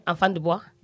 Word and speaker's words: Enfant du Bois: Ok, a ver si lo digo bien, ¿eh? Enfant du Bois. – Eso es. Enfant du Bois, sí, sí, sí.